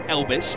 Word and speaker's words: Elbis: I feel like I'm saying Elvis every time I say his name, but it is Elbis, E-L-B-I-S.